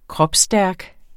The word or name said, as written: kropsstærk